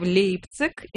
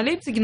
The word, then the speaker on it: Лейпциг: Почему-то, когда я произносила в присутствии своих немецких друзей название города Лейпциг, мне все время говорили неправильно, Лейпциг.